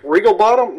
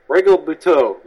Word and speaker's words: Regalbuto: I'm not going to say the name right. Regalbuto. – Regalbuto? Regalbottom? No, Regalbuto.